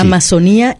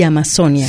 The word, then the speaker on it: Amazonia: Si es Amazonía, es una palabra que va con acento en la I. Y si es Amazonia, la palabra va sin acento.